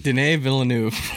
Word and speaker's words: Denis Villeneuve: Denis Villeneuve.